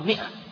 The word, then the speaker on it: مئة: وقد صنف أبو بكر الجبري وكان من العلماء الربانيين في أوائل المائة الرابعة المئة، ولو كتبت بالألف تنطق مئة، المئة الرابعة ولو كتبت بالألف، في أوائل المئة الرابعة. وهذا الذي نسمعه أحيانا من بعض الناس ماء غلط، وإنما هي مئة، ولو كتبت بالألف ممدودة تنطق مئة، وإن كان الصواب الذي يترجح عندي أنها تكتب على كرسي موصولة بدون ألف مئة.